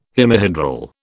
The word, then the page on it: hemihedral: British English